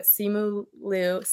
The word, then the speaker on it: Simu Liu: And then I might be pronouncing this wrong, but Simu Liu.